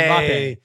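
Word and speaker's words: Mbappé: So you're familiar, I don't want to butcher his name, Mbappe? – Mbappe, right? – Yeah.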